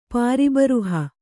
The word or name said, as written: ಪ್ರಬರ್ಹ